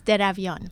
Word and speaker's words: Deravian: Daravian.